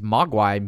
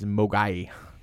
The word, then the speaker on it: Mogwai: So, in Cantonese, Mogwai means devil, demon, or gremlin. And the Mandarin pronunciation is Mogai.